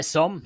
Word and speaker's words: Somme: Somme. Am I saying that right? – So yeah, Somme. Yeah, the Battle of the Somme. Yeah, that's the right way.